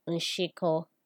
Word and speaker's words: nshiko: The last Udamkpi we talked about was SH, which is pronounced she in Igbo. She. An example of a word with the she sound is nshiko, which means crab.